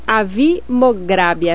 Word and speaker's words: Avi Mograbi: Oi, professor, o festival esse ano, ele vai homenagear o documentarista israelense Avi Mograbi, é assim que pronuncia? – Exatamente.